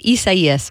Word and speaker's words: Isaias: And yes, I had to look up how to say it. Isaias, that is the name of, it's just a tropical storm right now.